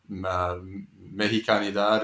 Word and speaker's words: Mexicanidad: So I wonder if you can talk a little bit about, you know, Jose Nápoles and Mexicanidad. Is that how you pronounce that word? – Yes, that's a very good pronunciation. Yes. – You're very generous. – No, it was. It was spot on.